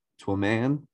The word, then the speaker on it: Twyman: It is T-W-Y-M-A-N, so to a man.